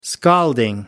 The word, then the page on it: scalding: American English